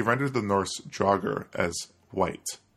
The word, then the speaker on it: wight: And over time, it came to be pronounced white, W-I-G-H-T.